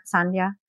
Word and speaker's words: Sandhya: Sandhya? – Yeah.